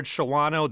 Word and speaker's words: Shawano: You look at it, it looks like a three-syllable word, Shawano. This stymied me for a long time. Joe, what happened? Where'd that syllable go? – It's just perfectly normal pattern of English. It's an unstressed syllable, and so you just lose it.